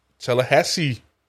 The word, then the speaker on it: Tallahassee: Og Tallahassee, Hassis, Tallahassee, sådan udtaler man det,